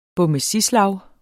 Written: bommesislag

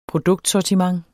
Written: produktsortiment

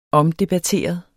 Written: omdebatteret

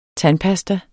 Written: tandpasta

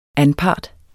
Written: anpart

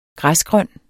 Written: græsgrøn

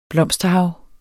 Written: blomsterhav

